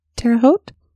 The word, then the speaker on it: Terre Haute: in I'm going to mispronounce this town, probably Terre Haute, Indiana, around 4 p.m. – Terre Haute? – That's it. Is that it? – I think it is.